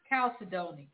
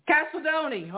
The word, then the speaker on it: chalcedony: Chalcedony. – Chalcedony, okay.